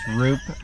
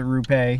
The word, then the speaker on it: Rupe: Nick Rupe, which it's pronounced Roop, but he says if you want to pronounce it Rupe, he don't mind.